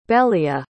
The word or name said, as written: belia